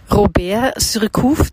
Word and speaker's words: Robert Surcouf: Robert Surcouf, der Tiger des Indischen Ozeans, habe ich das jetzt richtig gesagt? – Ja, genau.